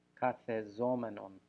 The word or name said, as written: kathezomenon